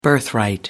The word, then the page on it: birthright: American English